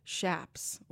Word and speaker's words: chaps: I learned last year while doing a Desert Dispatch about the Big Bend Ranch Rodeo that it's actually pronounced chaps, like chaparral.